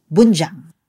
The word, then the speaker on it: Bunjang: Usually, nagkakamali po yung pronunciation nila ng Bunjang kasi B-U-N yung spelling niya. Pero ang tunay na pronunciation po niya ay Bunjang.